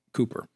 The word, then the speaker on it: Cowper: I mean, like highly recommend a biography by a gentleman it's a biography of William Cooper. It's spelled C-O-W-P-E-R. He wrote hymns with Isaac Newton.